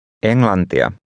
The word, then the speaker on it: Englantia: Englantia.